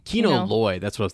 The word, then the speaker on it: Kino: They're like, when Lino, is that how you say his name? The leader, I forget his name. But Kino. – Kino, right? Yeah.